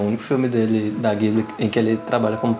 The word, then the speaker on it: Ghibli: E o nome, né, eu falo Ghibli, mas é, eu acho que a pronúncia correta é G giburi ou giburi,